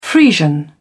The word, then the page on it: Friesian: British English